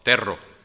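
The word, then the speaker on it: tero: It's called terro. Say that with me. Terro, terro. You got to roll your R a little bit.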